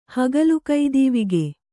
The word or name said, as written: ಹಗಲುಕೈದೀವಿಗೆ